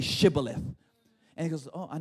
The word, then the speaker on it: Shibboleth: If the man said, no, I'm not, they would tell him to say Shibboleth. If he was Ephraim, he would say Sibboleth, because the people of Ephraim cannot pronounce the word correctly.